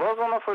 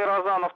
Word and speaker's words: Розанов: Значит, Владивосток представлял генерал Розанов или Розанов, там по-разному ударение ставят.